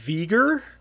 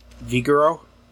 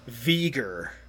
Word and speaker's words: Vegeir: I feel like I'm saying a slur, but it's V-E-G-E-I-R. How would you say that? – Say it again. V-E-G-I-R. – V-E-G-E-I-R. – Vigoro? – There's no O in there. Oh, where'd you get an O from? – I don't know. I thought you said O. – V-E-G-E-I-R.